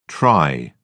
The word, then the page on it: tri: British English